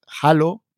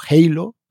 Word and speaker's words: Halo: Algunos se preguntarán que qué quiere decir Halo o Halo, cada uno que lo pronuncie como le apetezca.